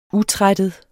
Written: utrættet

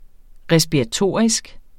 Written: respiratorisk

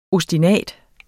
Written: OSTINATER

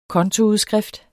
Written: kontoudskrift

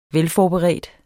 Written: velforberedte

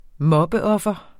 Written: mobbeoffer

